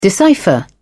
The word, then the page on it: decipher: British English